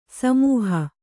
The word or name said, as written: ಸಮೂಹ